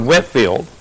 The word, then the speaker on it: Whitefield: It is spelled as Whitefield, but he went by the word Whitefield.